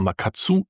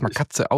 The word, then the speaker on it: Makatsu: Nee, man spricht es Makatze aus. Ich habe es extra. – Tatsächlich. – Das wird Meister Makatsu geschrieben. Die Aussprache ist aber Makatze auf Japanisch.